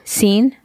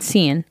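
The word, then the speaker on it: Cien: Scene? – Like bright. – Sean? Scene? I don't know how Lidl pronounces their name brand. It's C-I-E-N. Scene.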